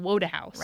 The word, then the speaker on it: Wodehouse: And then we did Mr. Woodhouse. – Mm-hmm. Great pronunciation, by the way, right there. – He should have been Wodehouse.